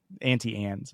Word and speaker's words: Auntie Anne's: Auntie Anne's is how it's pronounced.